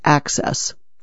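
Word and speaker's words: AHCCCS: System, or ACCESS, pronounced ACCESS.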